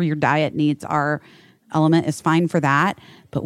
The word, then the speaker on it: LMNT: Guys, Element, in case you don't remember, Element LMNT is how it's spelled.